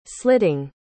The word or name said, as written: slitting